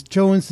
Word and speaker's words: Johne's: Jones disease is spelled Johne's.